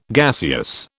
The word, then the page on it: gaseous: British English